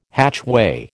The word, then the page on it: hatchway: American English